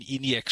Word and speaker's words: ENIAC: Wenn man sich zum Beispiel andere äh, relativ alte Computer an, zum, äh, zum Beispiel den äh, I e oder doch, wie wird er ausgesprochen? ENIAC, INIAC. E e ich, würde ihn ENIAC ENIAC sprechen, für Electronic Numerical Integrator and Calculator.